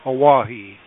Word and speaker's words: Oahe: not until the results came out from the panel review did I understand that there's not a concrete spillway for Oahe. Am I saying that correctly? – You are.